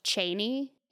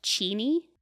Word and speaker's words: Cheney: Apparently, it's not pronounced Cheney. It's pronounced Cheney.